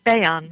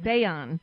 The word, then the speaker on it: Bayan: Yes, it's Bayan. – Bayan. Okay. Yes.